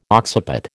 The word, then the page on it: occiput: American English